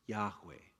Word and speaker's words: Yahweh: The pronunciation, eh, we're not 100% sure because we got the consonants and not the vowels, but we think, we think that the name was generally thought to be pronounced Yahweh. Yahweh.